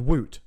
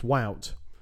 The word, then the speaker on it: Wout: And I thought his name was Woot. Because I was I was gutted. I wanted the chant to be Woot. There he is. – Yeah. – But it's not going to be. Apparently it's Wout, which rhymes with gout.